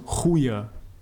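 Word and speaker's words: goede: Doch, sagen die Leute schon, aber tatsächlich wird es Chude, äh, wird äh, das D so ausgesprochen, als wäre es ein J im, im, sag ich mal, Sprachgebrauch, im Slang, also Chuje statt Aha.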